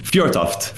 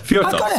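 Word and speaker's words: Fjørtoft: How do you say it? – Fjortoft. Fjortoft. So you were pretty close.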